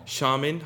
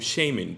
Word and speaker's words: shaman: 그 다음에 무당은 영어로 이것도 발음 두 가지 있어요. shaman 하고 shaman 둘 다 쓰는 것 같아요. So 둘 다 괜찮아요.